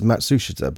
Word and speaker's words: Matsushita: Matsushita. Well, that's how us Brits would pronounce it, and I thought it was Matsushita, but maybe... – Matsushita. – Yeah, they're putting the emphasis on the su, aren't they? – That lady. – The Japanese people are putting the emphasis on the su, yes.